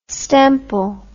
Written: stemple

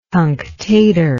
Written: cunctator

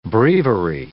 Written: breviary

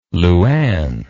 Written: Luanne

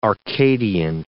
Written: arcadian

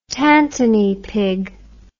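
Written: tantrika

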